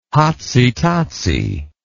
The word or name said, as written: hotsy totsy